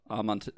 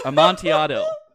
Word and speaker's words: Amontillado: Poor choice, but there are songs entitled The Raven, The Telltale Heart, and The Cast of Amont. – Still can't pronounce it. – I literally practiced this word and wrote it out phonetically, and I still can't say it correctly. Amontillado.